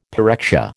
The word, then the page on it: pyrexia: American English